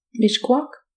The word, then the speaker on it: Mixcoac: Mixcoac.